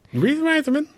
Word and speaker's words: Reitherman: Reitherman.